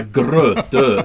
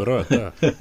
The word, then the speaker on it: Grötö: Och det måste alltså uttalas med såna här genuin göteborgska, va? Grötö heter det. – Grötö.